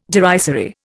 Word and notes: derisory: Indian

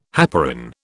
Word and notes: heparin: British English

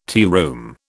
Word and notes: tearoom: British English

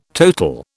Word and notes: total: British English

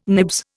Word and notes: nibs: Indian

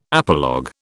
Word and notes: apologue: British English

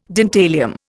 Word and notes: dentalium: Indian

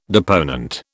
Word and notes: deponent: British English

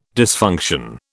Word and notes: dysfunction: British English

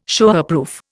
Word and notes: Showerproof: Indian

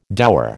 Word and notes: dower: American English

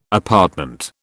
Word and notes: apartment: British English

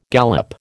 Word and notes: gallop: American English

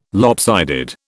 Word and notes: lopsided: British English

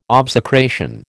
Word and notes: obsecration: American English

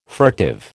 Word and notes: furtive: American English

